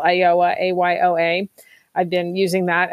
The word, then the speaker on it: Ayoa: It's Ayoa. It's spelled AYOA.